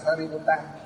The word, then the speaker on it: Sariputta: Tên tiếng Phạm là gì? Đọc là Sariputta.